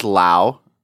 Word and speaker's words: Lowe: It is Lowe. Shocker, plot twist, I know, right? Everyone pronounces it wrong. Oh, the shirt I'm wearing right now says Lowe Cow. – Oh, my God. – It's just kind of like a little friendly reminder for people who don't know how to pronounce it.